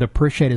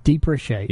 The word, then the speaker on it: depreciate: You say depreciate? – Depreciate. – Yeah. – Yeah, I'm sorry, I didn't say that right. Depreciate.